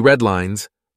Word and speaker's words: Red Lines: The Weekly Red Lines, rhymes with headlines, offers news you can use every Friday.